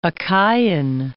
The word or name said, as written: Achaian